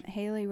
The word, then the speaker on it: Haylee: Haley Ryan's my Instagram. My name is spelled a little strangely, so H-A-Y-L-E-E.